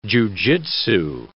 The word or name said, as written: jujitsu